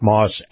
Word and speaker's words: Maus: In this segment, my discussion with Chris Markowski, the watchdog on Wall Street, is brought to you by our friends at Moss Acura. Moss is spelled M-A-U-S.